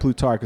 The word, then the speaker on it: Plutarch: Plutarch? Am I saying it right? – Well, they have it. Yeah, no, I think you're right. Plutarch, because they have it down, broken down.